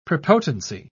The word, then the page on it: prepotency: British English